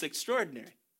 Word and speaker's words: extraordinary: The first Christmas was extraordinary, or extraordinary. I keep pronouncing that wrong. It's extraordinary.